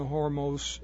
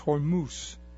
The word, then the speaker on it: Hormuz: are there to participate in the Hormuz. I'm mispronouncing that. It's Hormuz.